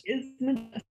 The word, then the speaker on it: Schismogenesis: Schismogenesis. – Schismogenesis? – Schismogenesis.